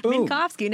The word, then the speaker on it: Minkovski: Minkovski.